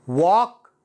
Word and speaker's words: walk: walk talk كود وود أعد أنا أنصحك أنك تعيد تنطقها مرة ثانية معي should walk talk could would ال لا ينطق would